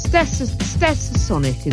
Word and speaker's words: Stetsasonic: This is Stetsasonic is how it's pronounced, and it's called All That Jazz.